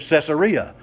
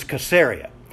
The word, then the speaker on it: Caesarea: See, all of us Gentiles have been pronouncing it Caesarea all these years, but it was Caesarea.